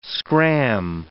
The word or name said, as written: scram